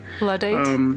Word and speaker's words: Luddite: I don't know how's the pronunciation. – Luddite. – Yes, merci. Thank you.